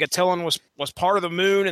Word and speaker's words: Attilan: And I'm going to pronounce this as Adalan. I don't know if you guys are going. – No, it's Attilan. – Now. All right. Attilan. – That's what it sounds like you're going to say. Now – Now right. – That's how I've always heard it pronounced.